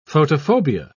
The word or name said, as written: photophobia